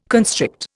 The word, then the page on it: constrict: Indian